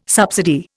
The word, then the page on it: subsidy: Indian